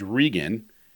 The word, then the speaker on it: Regan: My last name is Regan. And as soon as Ronald Reagan became president, no one could pronounce our last name. It was always Reagan. – Oh, right? – Yeah. – But you spell it R-E-G-A-N, right? – No, just R-E-G-A-N.